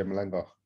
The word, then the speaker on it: Malanga: Today I am talking to BJ Malinga. Have I said that right? – Yeah, BJ Malanga. – Say it again. – Malanga. Malanga. Malanga. Malanga. You know, like Nolinga, Nolinga, Nolinga.